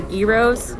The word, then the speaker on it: gyros: I really miss their gyros. That's how you pronounce it, is gyro, not gyro.